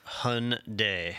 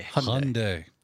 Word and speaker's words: Hyundai: According to the internet, it is pronounced Hyundai. – Hyundai.